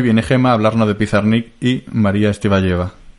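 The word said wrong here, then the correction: Tsvetáyeva: no, no, exactamente, no exactamente, se dice Svetayeva.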